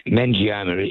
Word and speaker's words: meningioma: and it's called a meningioma. Is that... Am I saying that right? I just want to know your thoughts on that sort of brain tumour. – Well, meningioma is the correct.